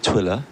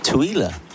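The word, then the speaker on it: Tooele: Well, Leslie's been in touch and says my grandson and his wife live in Twiller, and he says it's pronounced Twiller.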